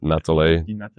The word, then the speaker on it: Natale: French is Natalie. – Natalie, even though it's got an E.